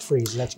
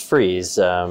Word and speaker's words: Fries: And actually, the book looks like it's Fries, but it's pronounced Freeze. – Pronounced Freeze. Freeze is a German name, and the pronunciation is like F-R-E-E-Z.